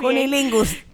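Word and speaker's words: cunnilingus: Muy complicado, el cunilingulus. lo dije bien. Cunilingus. cunilingus.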